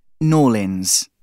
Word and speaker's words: New Orleans: They call it Norlins. That isn't a nickname. That's their pronunciation.